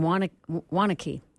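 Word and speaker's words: Waunakee: Tony from Wanakee, Wisconsin, I think that's right. Tony, tell me I'm saying that right. – You are saying it right.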